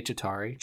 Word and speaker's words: Chitauri: Is it with a C-H? Like, no, it's a D, but it's spelled C-H. – I mean, it's spelled C-H-I-T-A-U-R-I. – Yeah. – So that's about right.